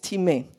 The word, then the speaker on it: timē: New Testament, the word is timē. Looks spelled like time, but it's timē.